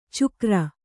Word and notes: ಚುಕ್ರ: cukra

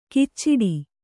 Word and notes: ಕಿಚ್ಚಿಡಿ: Kannada

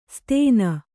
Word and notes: ಸ್ತೇನ: Kannada

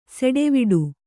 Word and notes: ಸೆಟ್ಟವಟ್ಟ: seṭṭivaṭṭa

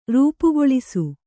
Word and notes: ರೂಪುಗೊಳಿಸು: rūpugoḷisu

